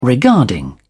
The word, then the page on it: regarding: British English